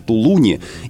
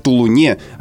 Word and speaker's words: Тулун: Дикторы действительно должны настаивать на норме, невзирая на местную традицию, ведь про Тулун говорят довольно продолжительное время, уже можно было выяснить, что наводнение было в Тулуне, а не гладить местных жителей против шерсти.